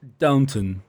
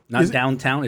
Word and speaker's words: Downton: And it's pronounced Downton, downtown. It's Downton.